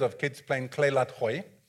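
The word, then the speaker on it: gooi: You have to kind of start yearing your throat. Khoi.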